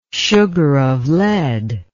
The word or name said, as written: sugar of lead